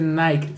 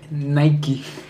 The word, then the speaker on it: Nike: Yo a Super Juli, la del MD, no se dice Nike, se dice Nike.